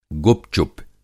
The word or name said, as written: गुपचुप